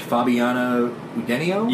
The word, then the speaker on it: Fabiana Udenio: But first, I hope I'm not butchering her name too much. Fabiana Udenio? – Yes.